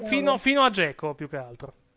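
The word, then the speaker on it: Džeko: Dzeko si pronuncia Dzeko, sì.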